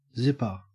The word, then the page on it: zipper: Australian English